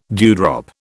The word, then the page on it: dewdrop: British English